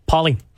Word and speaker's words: Pol: Paulie. – At Paul Blasino, P-O-L is how my first name is spelt, and my last name is spelt like it sounds.